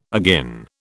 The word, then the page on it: agin: British English